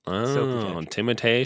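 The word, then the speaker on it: Timothée: Or appropriately called Timotei. – Is that how you actually pronounce his name? – Yeah, which is Timotei Chalamet. – Timotei Chalamet.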